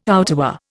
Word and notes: outerwear: Indian

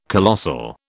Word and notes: colossal: British English

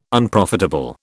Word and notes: Unprofitable: British English

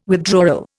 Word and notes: withdrawal: Indian

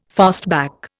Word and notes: fastback: Indian

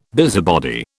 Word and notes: busybody: British English

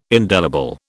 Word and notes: indelible: British English

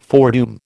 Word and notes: foredoom: American English

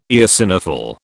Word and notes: eosinophil: British English

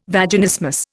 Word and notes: Vaginismus: Indian

